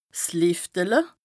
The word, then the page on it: 's Lìftele: Bas-Rhin; Strasbourg